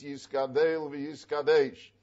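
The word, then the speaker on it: Yisgadel v'Yisgadesh (יתגדל ויתקדש): And therefore he says the correct pronunciation is Yisgadel v'Yisgadesh, because in the Novi, Yecheskel, it says v'hiskadilti v'hiskadishti. It doesn't say v'hiskadalti v'hiskadashti. And the chirik later becomes a tzera, and therefore we say yiskadel v'hiskadesh.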